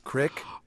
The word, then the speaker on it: creek: How do you pronounce that stream, which is smaller than a river, and is spelled C-R-E-E-K? Does it rhyme with meek? Does it rhyme with kick? – Crick. – Or do you not say either one? Do you say brook? – Okay, I guess I would say creek.